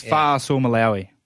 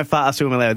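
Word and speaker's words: Fa'asuamaleaui: I thought the last bit was wrong. It's Fa'asua Malia'ui. – Fa'asua Malia'ui. – Yeah. – Tino Fa'asua Malia'ui. This is the official, because I've even been sent audio from the Melbourne Storm today, how to say it officially. And this is how we're supposed to say Tino's name.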